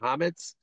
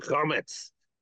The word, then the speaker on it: chametz: how do you say it? Chametz? – Comments, yeah. – Oh, chametz.